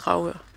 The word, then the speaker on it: Dragør: De skriver, at vi udtaler Dragør forkert. – Dragør. – Men jeg mener altså, det er Dragør.